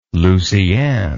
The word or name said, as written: Lucianne